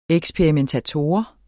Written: eksperimentator

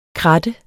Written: krat

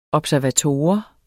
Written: observator

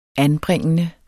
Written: anbringende

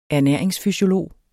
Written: ernæringsfysiolog

